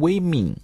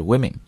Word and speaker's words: women: 注意也不要读成 women, 啊，读得非常的那个，应该来讲那个一特别长，不是，是 women 就可以了。